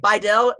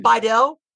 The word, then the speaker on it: Biedel: Yeah, that's where I get my, I'm Irish too, but my last name, Biedel, is German. By the way, my last name, Biedel, it's actually pronounced Beutel in Biedel.